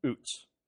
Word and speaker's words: Uz: uh, I decided I better figure out how to pronounce it, and that is how you pronounce it. Okay, I may revert back to the uh, what I would say, you know, oh, that's how you do it. But anyway, it's boots without the B, oots.